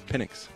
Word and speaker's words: Penix: Penix.